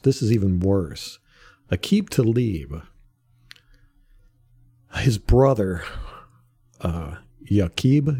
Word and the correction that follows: Yaqub: ya- Yaqub, Y A Q U B.